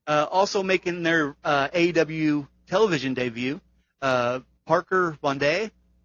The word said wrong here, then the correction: Boudreaux: Boudreaux. – Yeah, I can never pronounce that guy's name.